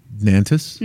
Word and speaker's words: Nantes: N-A-N-T-E-S. How do you say that? – Nantes. – Nantes. Okay, Nantes, France.